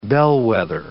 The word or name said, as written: bellwether